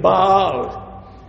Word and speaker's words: Baal: That the Lord, that's how you, that how you pronounce that name, Baal. It's not Baal, or how other people. I grew up with Baal because that's how it's written.